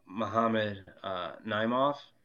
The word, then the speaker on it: Muhammad Naimov: That guy that he fought, Muhammad Naimov, I believe is how you pronounce his name. – Yep.